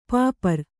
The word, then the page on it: ಪಾಪರ್: pāpar